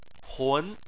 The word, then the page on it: hun: 2